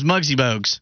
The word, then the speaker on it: Muggsy Bogues: No, his name is Muggsy Bogues. – Oh, you actually said it right now. – Yeah, I now can pronounce his name correctly.